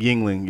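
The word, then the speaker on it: Yuengling: That's how you say it, Yingling? – Yingling, yeah.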